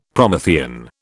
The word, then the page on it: Promethean: British English